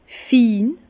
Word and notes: fin: Bas-Rhin; Herrlisheim

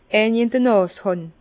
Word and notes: eini in d'r Nàs hàn: Bas-Rhin; Herrlisheim

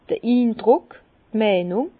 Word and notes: d'r Indruck, d' Meinung: Bas-Rhin; Herrlisheim

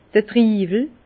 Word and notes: d'r Triiwel: Bas-Rhin; Herrlisheim

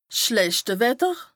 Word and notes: 's schlechte Wetter: Bas Rhin; Strasbourg

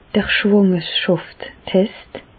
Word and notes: d'r Schwàngerschàfstest: Bas-Rhin; Reichshoffen

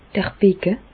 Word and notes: d'r Becke: Bas Rhin; Reichshoffen

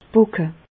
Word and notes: d' Pocke: Bas Rhin; Reichshoffen

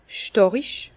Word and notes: stàrig: Bas-Rhin; Herrlisheim

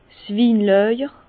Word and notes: 's Winlàjer: Bas-Rhin; Herrlisheim

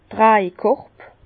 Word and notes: d' Trajkorb: Bas-Rhin; Herrlisheim

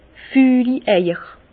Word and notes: fülli Eier: Alsatian; Bas-Rhin; Herrlisheim

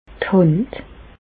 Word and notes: d' Hànd: Bas-Rhin; Reichshoffen

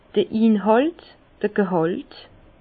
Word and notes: d'r Inhàlt, d'r Gehàlt: Bas-Rhin; Herrlisheim

